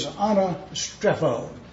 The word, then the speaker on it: anastrepho: The first word is anastrepho.